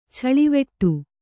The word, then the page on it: ಛಳಿವೆಟ್ಟು: chaḷiveṭṭu